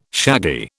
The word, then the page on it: shaggy: British English